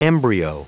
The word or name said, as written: embryo